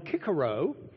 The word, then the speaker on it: Cicero: And the most prolific author we have now is who we would call Cicero, but the Latins would have called him Cicero, because they had a hard C, not a soft one.